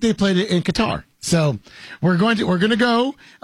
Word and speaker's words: Qatar: The most common and accepted English pronunciation of whatever the country's name is Qatar. Qatar is the phonetics.